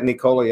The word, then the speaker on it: Nikola: Is it Nikola? Is that how they pronounce that? – Yeah, Nikola.